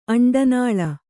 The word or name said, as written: ಅಂಡನಾಳ